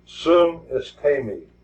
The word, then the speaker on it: sunistemi: It comes from a Greek word that's pronounced sunestemi. Sunestemi. It's pronounced that way, and it's spelled S-U-N-I-S-T-E-M-I.